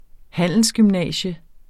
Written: Handelsgymnasium

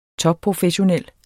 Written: topprofessionel

